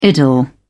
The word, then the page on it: idyll: British English